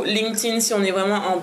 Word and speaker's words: LinkedIn: LinkedIn, ici on dit LinkedIn. LinkedIn, excusez-moi, j'ai mal, prononcé. LinkedIn LinkedIn.